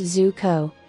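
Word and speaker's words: Zucco: George Zuko, pronounced Zuko. He was another of the character actors who never achieved real greatness, yet his face graced many a horror film of the 1940s.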